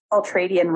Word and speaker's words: ultradian: Ultradian. So it looks like ultra and then D-I-A-N.